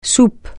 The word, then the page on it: soupe: francais